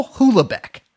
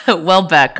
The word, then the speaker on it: Houellebecq: I'm actually going to pronounce it wrong on purpose. Michael Houlebecq. – No, Houlebecq. Okay. – Well, yeah. Okay. – Let's just say Houlebecq.